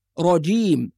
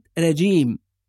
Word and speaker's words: الرجيم: ثم نأتي إلى كلمة الرجيم، وحرف الراء إذا كان مفتوح لابد أن يفخم، نقول را ولا نقول را، رجيم وليست رجيم.